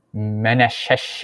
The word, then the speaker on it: Manasseh: And then when you take a look at the Strongs on Manasseh and Ephraim, how do you say that? – Manasseh and Ephraim.